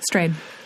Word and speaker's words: Strayed: One thing that Cheryl Strayed, am I mispronouncing that? – No, Strayed? I don't think so. Strayed.